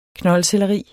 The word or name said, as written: KNOLDSELLERI